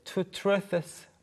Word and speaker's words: truths: To, 원래는 그냥 truths 발음이잖아요. – All right. – 그런데 to truths 발음하기가 너무 어려워요. – Yeah, to truths. – 네, 그래서 truths. 그냥 S가 더 강조되는 느낌. Yeah. To truths. – To truths. – To truths. – Truths. – 네, 그렇게 발음이 됩니다.